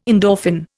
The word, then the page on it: endorphin: Indian